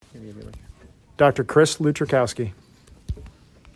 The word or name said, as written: Chris Lutrzykowski